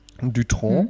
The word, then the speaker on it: Dutronc: Ähm, Ohne C, glaube ich, das C spricht man nicht. Dutronc.